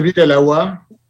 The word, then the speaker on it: Bir El Awa: En fait, c'est bir El Awa, ce qui veut dire en espagnol faire bouillir l'eau.